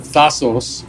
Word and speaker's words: Thassos: So it's on the island of Thassos, which rhymes with Yasos.